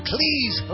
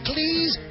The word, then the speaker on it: Cleese: Hear the full interview with John Cleese. Rhymes with cheese. Cleese. Cheese.